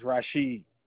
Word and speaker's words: Rasheed: My name is Rashid Ali. – Can you spell that for accuracy, please? – Yes. R-A-S-H-E-E-D.